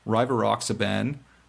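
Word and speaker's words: Rivaroxaban: These are Dabigatran, the trade name is Pradax, Rivaroxiban, trade name is Xarelto, and Abixaban, and the trade name is Eliquis. Did I say that right? – Eloquently.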